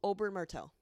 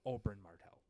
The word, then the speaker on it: Oberyn Martell: Oh, Oberyn Martell. – Oberyn Martell.